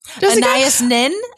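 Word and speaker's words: Anaïs Nin: Anais Nin.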